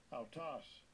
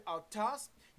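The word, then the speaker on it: autos (αὐτός): Strong's G 846, autos, autos. – This word on the Greek side, Strong's Hebrew Greek Dictionary, autos, means himself, herself, themselves, itself, he, she, it, the same.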